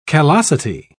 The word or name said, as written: callosity